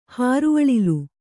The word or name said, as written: ಹಾರುಅಳಿಲು